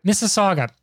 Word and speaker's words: Mississauga: So one thing that I know by virtue of having family that live outside of Toronto in Mississauga, I can never pronounce it right. – Mississauga? – Mississauga. There, we'll go with that one.